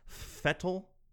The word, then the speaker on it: Vettel: It was nice to see Sebastian Vettel, which I believe is actually pronounced Fettel. That's effed me up for a long time because it's spelled V-E-T-T-E-L, and they always say Fettel.